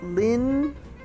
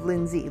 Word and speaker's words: Lyndsay: And Lindsay is spelled L-Y-N-D-S-A-Y. S-T-A-N-L-E-Y, Lindsay Stanley. It looks like Lin-Zay instead of Lindsay, Lin-Zay. But it's spelled L-Y-N-D-S-A-Y.